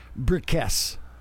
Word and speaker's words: Bricusse: The last name is Bricusse. It's pronounced Bricusse.